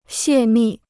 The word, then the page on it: 泄密: xiè mì